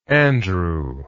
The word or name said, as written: Andrew